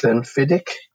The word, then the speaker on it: Glenfiddich: And Glenfiddich is actually pronounced Glenfiddich. – Oh! – There you go. – Wow! – It's a common mistake for Americans to make.